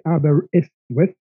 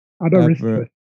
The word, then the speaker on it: Aberystwyth: In Wales, are you at, I don't know how to pronounce it, Aberystwyth? – Imagine the Y's, if you see the word, as I's. So if you say Aberystwyth, that's spot on. – Aberystwyth is the name of the river.